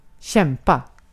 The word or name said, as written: kämpa